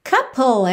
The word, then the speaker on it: couple: Couple, essa é uma palavra importante também. É para você aprender porque a escrita não bate perfeitamente com o som se você ainda não tem muita intimidade com o inglês. Então, essa palavra, que significa casal, se escreve C-O-U-P-L-E. C-O-U-P-L-E. Mas a pronúncia é couple.